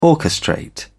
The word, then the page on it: orchestrate: British English